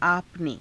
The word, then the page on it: आपने: aapa ne